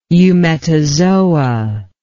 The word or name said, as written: eumetazoa